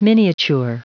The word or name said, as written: miniature